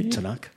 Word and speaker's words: Tanakh: So So yeah, so that's the sort of lead up to the Bible period we get to, and when we get to how do you pronounce it? The Tanakh by the Jew. – Tanakh.